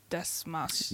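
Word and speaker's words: Dastmalchian: Dasmalchian.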